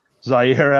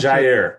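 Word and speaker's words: Jaire: So is it Zaire Alexander or is that how you pronounce Jair. – It? – Jair. – Jair. – With a J. Okay. Yeah. – With a J, yeah. So I guess what happened is going out for the coin toss, he made himself the captain without telling anyone.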